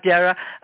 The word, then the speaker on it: Dara: It's Dara, like Sarah, but I answer to either. – Okay. All right, Dara.